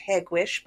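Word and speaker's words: Hegewisch: Now, according to Wikipedia, which is where I usually like to take a lot of this information, is place locations, it is pronounced Hagwish by the locals.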